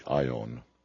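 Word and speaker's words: aion (αἰών): It's the word age, pronounced in Greek, aion. Comes into English as eon, I guess, most directly.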